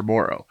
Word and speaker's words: Moreau: And said it's actually supposed to be Foster Moreau.